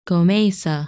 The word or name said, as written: Gomeisa